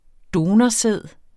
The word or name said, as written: donorsæd